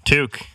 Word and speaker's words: Toque: I'm going to butcher the pronunciation. Toque? Toque? – Toque.